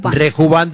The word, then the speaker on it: Rejuvant: Se escribe Rejuvant, Rejuván de rejuvenecer. Rejuván.